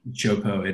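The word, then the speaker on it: Teahupo'o: Chopo, it actually starts with a T, but it's pronounced Cho.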